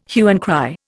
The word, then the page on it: hue and cry: Indian